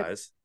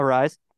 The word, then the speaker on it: Arraez: Just to throw this out there, Luis Arraez. Arraez. How do you say Arise. – It? Arise. Thanks. Has a 4.8K percentage.